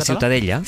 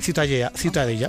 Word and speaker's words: Ciutadella: ¿cómo se pronuncia? – La Ciutadella. – Ciutadella. Bueno, pues Ciudadella también en castellano.